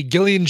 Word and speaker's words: Gillian: Hey, Gillian Jacobs pronounces it with a hard G from Community.